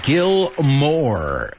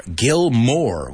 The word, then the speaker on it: Moore: Gil Moore. We looked at the word Moore before, right? In our homophone section. Pues esto como apellido, M-O-O-R-E, se pronuncia al menos en inglés americano como más, right?